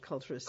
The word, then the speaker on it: culturist: And, and um, you know, and then you have the more um, advanced kind of ideas like the culture sys guy who. Mm-hmm um, didn't uh, I think I'm not pronouncing that right, culturist. – Culturist, yeah.